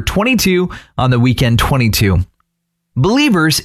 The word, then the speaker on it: BLVRS: B-L-V-R-S. It's Believers without the vowels.